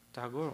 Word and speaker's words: Tagore: Tagore in Bengali is pronounced as Tagore or Tagore? Tagore only.